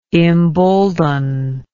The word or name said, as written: imbolden